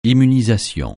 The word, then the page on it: immunisation: francais